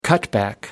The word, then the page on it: cutback: American English